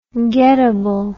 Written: gettable

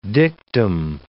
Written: dictum

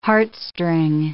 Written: heartstring

